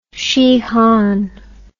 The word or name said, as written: shihan